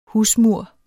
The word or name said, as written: husmur